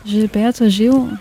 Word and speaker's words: Gilberto Gil: Gilberto Gil, so spricht man ihn aus, glaube ich. Ne? – Gilberto Gil, so um den Dreh. – Das klang richtig.